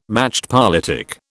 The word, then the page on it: machtpolitik: British English